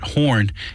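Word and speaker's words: Horne: Important to remember that Horn is with an E at the end of it. – Yes. E is silent, I should add.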